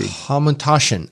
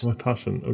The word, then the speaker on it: Hamantaschen: Hamantaschen. I was close. – Hamantaschen.